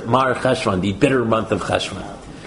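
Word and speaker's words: Marcheshvan: Mar Cheshvan. – Right, so there's a funny the month of Cheshvan, which really ought to be pronounced Marach Shavan.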